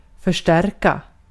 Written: förstärka

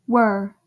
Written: were

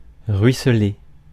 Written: pour

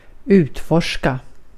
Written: utforska

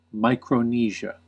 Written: Micronesia

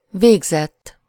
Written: done

